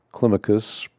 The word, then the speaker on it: Climacus: Said John Climacus, or Climacus, properly pronounced, the remembrance of Jesus' sufferings cures remembrance of wrongs, which is mightily shamed by his forbearance.